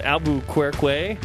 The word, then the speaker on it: Albuquerque: I'm glad and relieved you got that pronunciation of Albuquerque right.